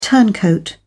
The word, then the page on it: turncoat: British English